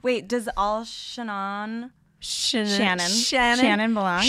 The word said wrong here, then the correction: Chenin Blanc: Shannon Doherty? – I'm going to learn how to say it. – Chenin Blanc come from Loire Valley.